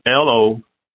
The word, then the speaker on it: Lopaze: It's Lopaze. If you type my first name in on Twitter, I should pop up. – All right. So spell that one more time, Lopaze. – Lopaze.